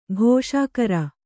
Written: ಖುಷಿಗಾರ

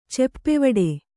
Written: ಚೆಪ್ಪೆವಡೆ

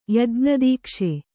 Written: ಯಜ್ಞದೀಕ್ಷೆ

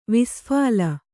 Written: ವಿಸ್ಫಾಲ

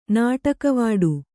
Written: ನಾಟಕವಾಡು

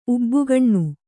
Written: ಉಬ್ಬುಗಣ್ಣು

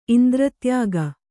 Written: ಇಂದ್ರತ್ಯಾಗ

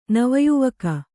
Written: ನವಯುವಕ

